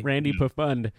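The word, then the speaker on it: Randy Pfund: Was there a silent P in that dude's name? Was it Randy Pafund? – I think it was a P-F-U-N-D. – P-F-U-N-D. – Yeah, Randy Pafund.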